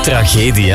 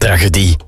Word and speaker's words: tragedie: tragedie, hè? Zo spreek je dat uit. Ik had de tragedie gezegd. Jan uit Merkspas vroeg zich al af of hij het al heel zijn leven fout uitspreekt. En ik het juist heb. Nee, tragedie. Niet tragedie.